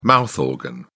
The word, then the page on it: mouth organ: British English